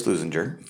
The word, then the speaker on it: Leuzinger: This week, we are joined by Chris Leuzinger from Hopefully, I said that right, Chris. – You did, yeah.